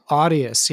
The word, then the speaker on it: Audius: Audius? – Audius, yeah. And how do you spell that? A-U-D? – A-U-D-I-U-S.